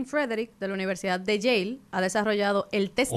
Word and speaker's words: Yale: De Yale. – Eso se escribe Yale. – Yale. – Como el candado.